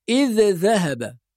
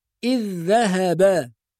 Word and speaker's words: إذ ذهب: كذلك في قوله تعالى إذ ذهب، بعض الناس بيظهر الذال الأولى فيقول إذ ذهب، وهذا خطأ، لأن الذال الأولى تضغم في الثانية، يعني ننطق ذال واحدة مشددة.